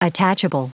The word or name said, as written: attachable